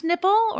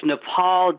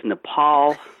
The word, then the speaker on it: Nippal: PJ Byrne, Professor Warren, is it pronounced Nippal or is it Nepal? – It's Nepal, damn it. It is Nepal.